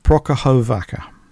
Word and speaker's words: Prokhorovka: So we're going to, I'm going to concentrate on an incident that occurred, and I think you pronounce the town's name Prokhorovka.